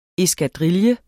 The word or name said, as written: eskadrille